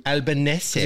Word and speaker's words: Albanese: How do you pronounce it? Albanese or Albanese? – Albanese. – You said it's not, you don't pronounce it Bolognese. That's how we said how to pronounce his name. Albanese. Albanese or something like that.